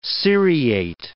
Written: seriate

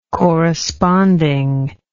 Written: corresponding

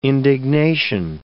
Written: indignation

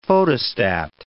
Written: photostat

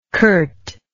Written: Kurt

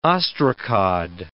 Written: ostracod

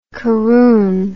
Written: caroon

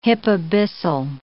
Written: hypabyssal

